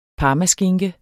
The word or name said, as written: parmaskinke